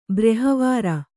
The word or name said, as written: ಬ್ರಿಹವಾರ